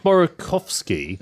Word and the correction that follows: Borkowski: I probably completely mispronounced the name, but the Borkowski, sorry, the PR expert.